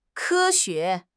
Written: ke1 xue2

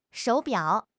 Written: shou3 biao3

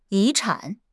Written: 遗产

